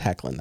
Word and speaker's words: Hoechlin: One was that, you know, Tyler Hoechlin's Superman, and I looked up a YouTube video on how to pronounce his name, and it is Hoechlin. That's how he pronounces it.